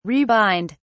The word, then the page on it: rebind: British English